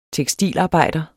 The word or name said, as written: tekstilarbejder